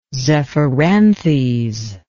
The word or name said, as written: zephyranthes